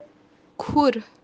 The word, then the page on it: khur: kashmiri